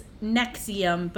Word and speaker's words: NXIVM: The topic is Nexium, but it's spelled all caps NXIVM.